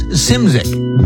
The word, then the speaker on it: Szymczyk: He spells his name S-Z-Y-M-C-Z-Y-K, and it's pronounced Simzik, Bill Simzik.